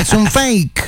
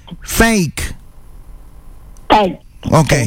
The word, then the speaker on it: fake: Ahí está, es un fake. – No, Instagram. – Mi amor, un Instagram falso. Falso, se dice fake. Se escribe faque.